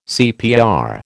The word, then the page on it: CPR: American English